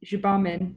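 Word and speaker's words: Gbamin: And then you've got Gambian. I may have pronounced that incorrectly. – Jibamin. – Yeah, you've got Jibamin.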